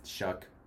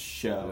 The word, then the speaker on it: Shough: Shuck. – It's Shuck? – Shuck. Tyler Shuck. – That's definitely pronounced Shough. – No, that's Shuck.